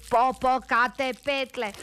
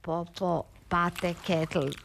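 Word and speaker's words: Popocatépetl: Reci popokate petle. Ona jadnica uporno govorila popopate ketl. Rekao je, glupača si, ne znaš izgovoriti ni popokate petle, a osim svega imaš ove mizerno male grudi.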